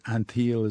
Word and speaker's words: Antheil: Now, amazingly, in her spare time, she was an amateur inventor with the composer, I think George Antheil is the way you pronounce it.